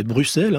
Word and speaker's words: Bruxelles: Bon, non, c'est Bruxelles. Hein, c'est deux S.